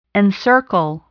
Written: encircle

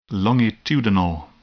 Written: longitudinal

